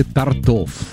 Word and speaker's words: тортов: Куда ставить ударение? Торты или торты? – Торты. – Тортов или тортов? Как мы все-таки... – Тортов. – То есть торты в первом случае, а во втором случае тортов.